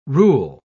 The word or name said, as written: rule